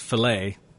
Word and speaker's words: filet: I realize that it's spelled F-I-L-E-T, but it's pronounced fillet here anyway.